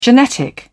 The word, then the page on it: genetic: British English